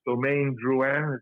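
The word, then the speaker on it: Domaine Drouhin: it's Domaine Drouin. Is that how you pronounce yes, it? Yes, mm-hmm.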